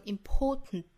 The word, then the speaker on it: important: 那这里面我们可以读成 important，用鼻子去呛一下发声 important important important。当然我们也可以选择不去发鼻腔爆破，直接发成 important 也是可以的。